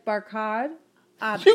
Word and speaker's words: Barkhad Abdi: I said Barkhad. Abdi. – You can't say it right now. You're like, Chuck. – It's Barkhad. Like, it's Barkhad Abdi.